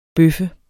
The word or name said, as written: bøf